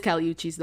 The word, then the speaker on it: Kali Uchis: But yeah, no, it is Kali Uchis though. That is how you pronounce it. But yeah, she pronounces it like with the Spanish accent and stuff and all that.